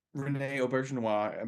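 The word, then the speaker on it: René Auberjonois: Rene Aubergenois, I'm saying his name right, probably not. – You are, actually. You are. – Yeah, you said it right.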